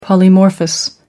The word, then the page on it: polymorphous: American English